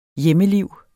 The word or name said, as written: hjemmeliv